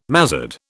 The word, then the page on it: mazzard: British English